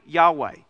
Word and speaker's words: Yahweh: And biblical scholars nowadays, they think that the best possible pronunciation of the name of God is most likely Yahweh.